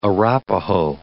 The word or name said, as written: Arapaho